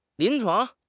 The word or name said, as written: 临床